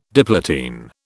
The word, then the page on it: diplotene: British English